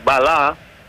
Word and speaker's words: Μπαλά: Σεραφίμοφ θα δώσει στον Έβερτον Μπαλά, όπως προφέρεται το όνομα του Βραζιάνου επιθετικού.